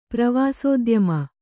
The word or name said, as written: ಪ್ರವಾಸೋದ್ಯಮ